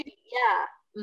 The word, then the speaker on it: congee: So maybe trying to eat congee, is it congee? Is that how you pronounce it? – Congee, yeah.